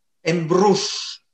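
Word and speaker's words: Embruix: Ja, wie spricht man das richtig aus? Frage nach Spanien. Embruch? – Embruch. Embruch. Embruch. Das heißt, das ist die ist katalanisch und das auf Deutsch kann man sagen, ist verzaubert.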